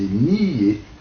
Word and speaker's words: nier: Donc, deux syllabes en ancien français, nier. Mais je vous dis, alors, dans le cours du XVIe, XVIIe siècle, alors, prononciation actuelle qui s'installe, n'y est une seule syllabe.